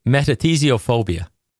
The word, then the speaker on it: metathesiophobia: And that word is metathesiophobia. And I'll spell it out for you. It's meta, M-E-T-A-T-H-E-S-I-O, T-H-E-S-I-O, phobia, P-H-O-B-I-A.